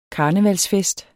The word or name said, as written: karnevalsfest